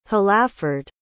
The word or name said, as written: hlaford